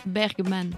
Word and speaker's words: Bergman: Egmar Egmar Bergman B-E-R-G-M-A-N. – Ouais. – Bergman.